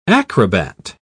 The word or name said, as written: acrobat